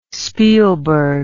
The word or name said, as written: spielberg